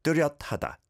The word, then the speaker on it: 뚜렷하다: 상디긋 on the 뚜. Do, do, like do, do, do, do, when you're doing Blackpink's song, right? And then, ryot, ryot, there's a shiot batch in there. So, 뚜렷하다, 뚜렷하다.